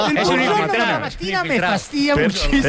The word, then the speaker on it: per la mattina: Es buongiorno por la mattina. Que decís siempre. – Buongiorno por la mattina. – Per la mattina, no por la mattina.